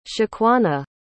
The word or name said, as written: shaquana